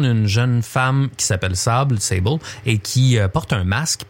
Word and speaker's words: Sable: Sable, ou prononcé en anglais Sable, c'est un jeu qui est disponible évidemment sur sur Steam, sur Epic Game Store.